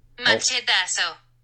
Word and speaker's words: Machetazo: Machetazo. – Machetazo. – Oh, okay. – Machetazo. – There, could you hear that through the mic? – Yes, machetazo.